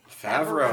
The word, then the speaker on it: Favreau: Favreau.